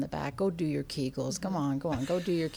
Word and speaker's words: Kegels: Actually, the gynecologist who first described that passed away many, many years ago, so I'm not sure how he pronounced his last name, but we usually say Kegels.